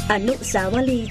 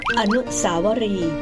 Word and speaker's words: อนุสาวรีย์: อนุสาวรีย์ชาวบ้านบางระจันอนุสาวรีย์ชัยสมรภูมิคำว่าอนุสาวรีย์